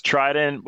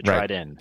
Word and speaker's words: Triden: And that's Triden without a T on the end. So Triden.